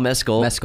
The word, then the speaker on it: Mescal: Yeah, don't say it like Mescal. – Paul Mescal.